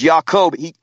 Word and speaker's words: Yaakov: Yah changed it from Yaakov. Okay, that's how you really pronounce it. Instead of Jacob, there's no J, right? Is Yaakov.